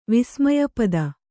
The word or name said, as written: ವಿಸ್ಮಯಪದ